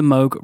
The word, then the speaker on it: Moog: Most of you have probably heard his name before, although you might have heard it pronounced as Moog. – Moog. – Moog. – But it's definitely pronounced Moog.